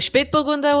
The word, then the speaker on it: Spätburgunder: Qual que é a diferença do PN e do Spabangander? Como é que fala isso? É isso? Falei certo? – É o Spätburgunder ou o Pinot Noir?